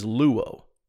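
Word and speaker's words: luo (λύω): The root word in the Greek for interpretation is luo, L-U-O, we'd spell it.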